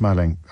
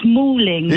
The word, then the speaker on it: Malling: Is in West Malling. How do you pronounce that? – It's Malling.